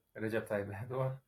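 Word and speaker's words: Recep Tayyip Erdoğan: Recep Tayyip Erdogan.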